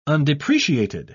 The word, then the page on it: undepreciated: British English